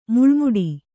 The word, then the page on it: ಮಳೆಮೋಡ: maḷe mōḍa